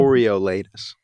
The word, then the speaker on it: Coriolanus: Tom Blythe as how do you say it? Coriolanus Snow. – Coriolanus.